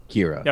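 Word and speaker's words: Qi'ra: Kira.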